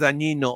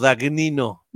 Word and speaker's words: Dagnino: Oigan todos, pero más tú, Valeria Torices, ya pusieron nuevo director del SAT o jefe del SAT, Antonio Martínez Dañino o Dagnino. Uh-huh. Es el nuevo. ¿Dagnino? Es que, güey, GN se pronuncia como Ñe, como ñ, güey.